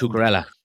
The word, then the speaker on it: Cucurella: And they only lost Cucurella.